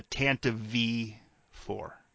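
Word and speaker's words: Tantive IV: I'm going to side with most of you guys and say, and by the way, everyone's pronouncing it wrong. It's the Tanta V4, just so everyone knows.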